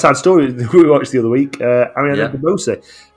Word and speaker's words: DeBose: No, it is DeBose. Ariana DeBose. – DeBose. – And DeBose, apparently.